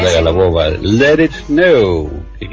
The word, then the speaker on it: Let It Snow: No, con R no. Let It Snow, Let It, Let It.